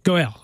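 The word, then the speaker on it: Goel (גואל): By the way, how is that pronounced in the Hebrew? I'm not really good at my Hebrew pronunciation. What's that? – Goel? – Goel. Okay, yes.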